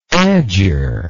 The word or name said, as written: agger